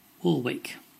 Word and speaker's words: Warwick: W-A-R-W-I-C-K. Now, how to pronounce this place name? It is not Warwick. It is Warwick. Okay, a bit of a challenge now. B-I-C-E-S-T-E-R.